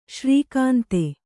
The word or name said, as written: ಶ್ರೀಕಾಂತೆ